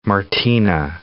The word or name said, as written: Martinu